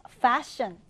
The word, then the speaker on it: fashion: 자, fashion이라는 단어, 이 단어 F 발음을 해주셔야 됩니다. 우리는 패션이라고 얘기를 하는 경우가 있지만 이 passion이란 단어는 따로 있어요. P 발음으로 할 때는 열정이라는 뜻의 단어이고 한편 이렇게 F 발음을 해야 fashion, fashion이란 뜻이 된다. 또는 유행, 인기라는 뜻도 됩니다.